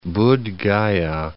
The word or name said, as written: Buddh Gaya